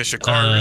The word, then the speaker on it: Sha'Carri: Anyways, Sha'Carri. I think that's how you say her name. – Yeah, Sha'Carri Richardson.